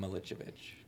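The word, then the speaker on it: Miličević: It's Adam Milicevic. – Milicevic. – Yeah. – Milicevic. – The C's are C-H's.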